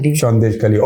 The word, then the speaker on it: Sandeshkhali: She's visited Sandeshkhali as a reporter. Did I pronounce that right? – Yes. Sandeshkhali.